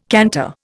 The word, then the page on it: canter: Indian